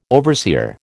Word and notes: overseer: American English

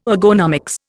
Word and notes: ergonomics: Indian

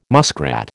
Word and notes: muskrat: American English

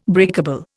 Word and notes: breakable: Indian

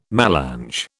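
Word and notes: mélange: British English

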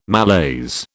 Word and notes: malaise: British English

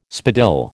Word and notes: spadille: American English

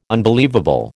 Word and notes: unbelievable: American English

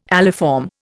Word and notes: aliform: Indian

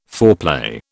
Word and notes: foreplay: British English

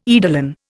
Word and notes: eidolon: Indian

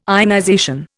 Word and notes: ionization: Indian

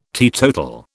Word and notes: teetotal: British English

